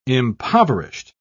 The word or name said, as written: impoverished